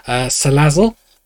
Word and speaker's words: Salazzle: For Sue's slot, she gave me the selection of Dragonite, Lapras, Noct Owl, Absol, Magnezone, and I'm going to pronounce this wrong, Salazzle. – No, that's right.